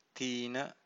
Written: thina

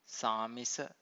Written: samisa

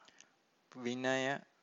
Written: vinaya

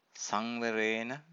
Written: sanvarena

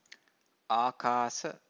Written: akasa

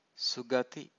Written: sugathi

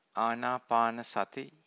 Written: anapanasati

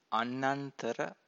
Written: annantara

